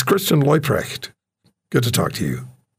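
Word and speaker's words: Leuprecht: Christian Loyprecht, not Luprecht.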